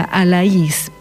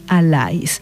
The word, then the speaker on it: Alais: Posiblemente la pronunciación original de ese apellido sea Alais, pero aquí es conocido como Juan Alais.